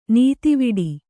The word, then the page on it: ನೀತಿವಿಡಿ: nītiviḍi